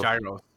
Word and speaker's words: gyro: Se escriben giros, son sándwiches griegos, como un taquito. – Gyro. Se llama gyro. – Giro. – Gyro. – No, yo estuve, yo fui a un restaurante griego, dicen giro.